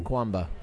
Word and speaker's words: Kamkwamba: Kamkwamba.